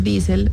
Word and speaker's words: diesel: Viene del nombre de Rudolf Diesel, el ingeniero alemán que inventó el motor diesel a finales del siglo XIX. Ah. Y en alemán se pronuncia diesel como con una I larga.